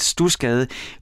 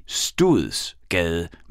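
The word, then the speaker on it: Studsgade: husk lige, at stusgade udtales stusgade, men der er et D i stuet, så i virkeligheden så burde man vel sige studs gade, men det hedder altså Stusgade. Det er sådan, man udtaler det.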